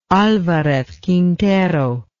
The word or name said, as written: alvarez quintero